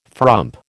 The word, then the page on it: frump: American English